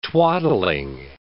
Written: twaddling

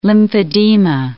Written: lymphedema

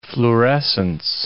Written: fluorescence

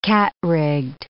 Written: cat-rigged

